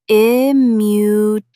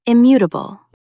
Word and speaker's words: immutable: Stress the second syllable. Immutable. Say it out loud. Immutable.